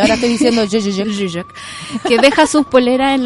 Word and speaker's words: Žižek: Eh, un científico como, o sea, un filósofo como Slavoj Žižek, porque pues se pronuncia así. – A ver, a ver, a ver, a ver de nuevo. Žižek.